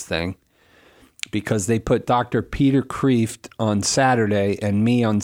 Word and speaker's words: Peter Kreeft: Peter Kreeft is the proper pronunciation, I'm told.